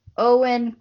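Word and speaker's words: Eoin: Okay, so our book for next month is Artemis Fowl by Owen Colfer. I had to look up how to say his name because it doesn't look like Owen. It's Irish, just so you know.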